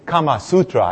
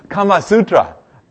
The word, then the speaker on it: Kama Sutra: Yes, not too many have heard of, actually, Kama Sutra. I messed up those syllables there. Okay, let's see the hands now. Ah, come on. Yes, yes. Interesting. Good. Kama Sutra, that worm, that malicious worm that some diabolical mind created to infect computers.